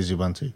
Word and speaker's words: Xubuntu: Yeah, I'd say Zubuntu. But is that laziness? It's like, I think it's partly laziness that I say Zubuntu, because it's easier, rather than, yeah, because I'm saving myself one syllable. If I say Xubuntu, it's longer than saying Zubuntu.